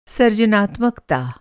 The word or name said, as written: सर